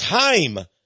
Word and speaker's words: Chaim: The man calls himself Chuck Lorre, but Charlie Sheen said his real name is Chaim, C-H-A-I-M, but they pronounce it Chaim in Israel and among the Jews.